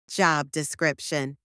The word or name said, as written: job description